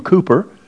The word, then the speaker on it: Cowper: In 1774, there was an English poet by the name we pronounce his name actually different than it's written, William Cowper.